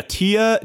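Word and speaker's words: Tyya: It's T-Y-Y-A, but it is pronounced as Tia, as they said here.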